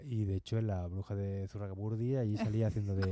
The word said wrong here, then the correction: Zugarramurdi: Zugarramurdi, ¿lo has dicho bien? – Zurragamurdi, lo he dicho bien.